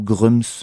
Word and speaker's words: Grems: ou Grums, je sais pas exactement comment, enfin moi je le prononce Grems mais j'ai entendu des gens qui disaient Grums, donc euh, vous faites comme vous voulez, hein, G-R-E-M-S.